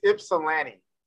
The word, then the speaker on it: Ypsilanti: So it's Ypsilanti. – Okay, I just have to remember the Lanny part.